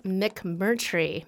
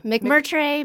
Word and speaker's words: McMurtray: How do you say your last name? McMurtry? – Okay, this is McMurtry. McMurtry, McMurtry. – McMurtry. I mean, yeah. It's kind of like it's spelled.